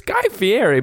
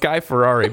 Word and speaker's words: Fieri: And I was like, it's Guy Fieri, but honestly, I like Guy Ferrari better.